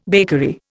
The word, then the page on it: bakery: Indian